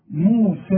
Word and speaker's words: موسى: طب ما هي موسى فيها إيه؟ والليل بتتحول إلى ألف في النطق. موسى. أنا لقيت إيه لما وصلت قلت موسى الكتاب. الحكم ده هتاخده فيما بعد. لكن إذا وصلت إلغي الياء مؤقتا. موسى الكتاب وكأن الياء غير موجودة.